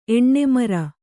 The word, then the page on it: ಎಣ್ಣೆಮರ: Kannada